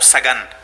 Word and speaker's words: Sagan: A gente em espanhol, a gente pronuncia o esse cientista como Sagan, Carl Sagan.